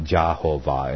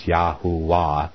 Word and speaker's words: Yahuwah: So, by his name Yah and his name Yahuwah, it's not Jehovah, it's Yahuwah, because the V was not pronounced.